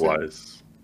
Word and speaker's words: Mogwais: Mogwais.